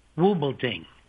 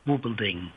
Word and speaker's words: Wubbolding: And his name is spelled, last name is spelled, and it's E-D, E-D-D. – Yes. – And his name is spelled W-U-B-B-O-L-D-I-N-G, Wub with a W-U-B and then Bulding, Wubelding.